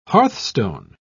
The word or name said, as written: hearthstone